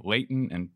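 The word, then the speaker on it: Leighton: Rich Finneran from Bryan Cave, Layton & Paisner. Am I pronouncing the additional two names correctly? – Yeah, right. They're new names. Yeah, but yes, you got them right.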